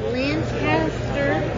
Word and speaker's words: Lancaster: Again, Lancaster.